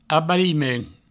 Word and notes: abariment: Provençal; abarimen

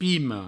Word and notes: pima: pimo

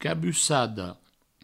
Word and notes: cabussada: cabussado

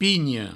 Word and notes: pinha: pigno

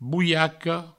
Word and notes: bolhaca: bouiaco

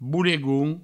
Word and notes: bolegon: boulegoun

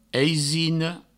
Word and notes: aisina: eisino

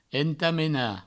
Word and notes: entamenar: entamena; provençal

